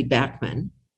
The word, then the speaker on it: Bachman: All right, so um, uh, but the Bachman part, okay, I, I, promised we'd come back to that because, as I said, I grew up calling you Randy Bachman, um, even though it's spelled CH.